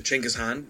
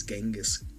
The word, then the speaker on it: Chinggis Khan: It's not, well, you're not using the original one if you pronounce it as Genghis.